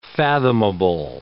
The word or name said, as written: fathomable